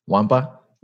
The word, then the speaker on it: Nwankpa: It's pronounced Wampa. The the K and the N are silent, so Wampa. – Wampa.